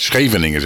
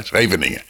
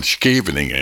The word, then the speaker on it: Scheveningen: Want Scheveningen kan je niet uitspreken, Karen. Scheveningen zeggen wij in Nederland. Hè. Scheveningen. En zeggen die Duitsers zeggen dan Scheveningen.